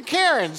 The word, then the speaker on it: cairn: It, by the way, it's spelled C-A-I-R-N. And it is a stack of rocks that marks out the trail.